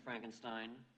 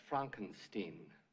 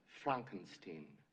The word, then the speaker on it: Frankenstein: I have one question, Dr. Frankenstein. That's Frankenstein. – I beg your pardon? – My name, it's pronounced Frankenstein.